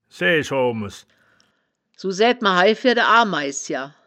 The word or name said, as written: saeaesch oaomes